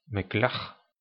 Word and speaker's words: Meklet: Meklet, qui se prononce en klingon Meklar, c'est une arme de corps à corps à une main, environ de la longueur d'un bras, de l'épaule au poignet.